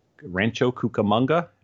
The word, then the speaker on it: Rancho Cucamonga: But they are put up, financed and put up in Rancho Cucamonga.